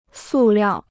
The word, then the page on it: 塑料: sù liào